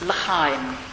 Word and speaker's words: L'chaim: The Jewish community has a particularly wonderful toast, which is pronounced L'chaim.